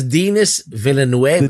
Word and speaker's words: Denis Villeneuve: Is that how you pronounce it? I've been pronouncing it wrong. – His name is Denis Villeneuve.